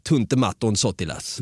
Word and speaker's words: Tuntematon Sotilas: Och du blev lite impad över att jag kunde Tuntematon Sotilas. – Det blev jag. För det uttalade väldigt bra.